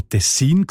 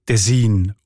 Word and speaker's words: Tessin: Und in diesem Dialekt sieht man Tessin oder Tessin, also auch auf der zweiten Silbe betont.